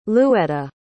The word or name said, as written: luetta